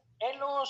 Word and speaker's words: Elo: Elo se pronuncia tal cual porque es un apellido, es el apellido de la persona que ideó eh, la fórmula mediante la cual se, calcula el rating.